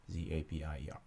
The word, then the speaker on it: Zapier: Zapier, yes, that's how you spell it.